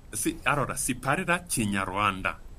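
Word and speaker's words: Kinyarwanda: Per, evang- per, evangelizzare in Ruanda bisogna conoscere Cignaruanda. – Cignaruanda.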